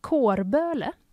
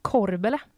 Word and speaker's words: Kårböle: eh som folk sa, Kårböle. Känner du igen det? Nej. Korbele ska man tydligen säga.